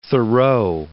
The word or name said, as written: Thoreau